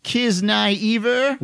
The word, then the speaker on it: Kiznaiver: I'm saying, Gerald, the pronunciation is Kiznaiver.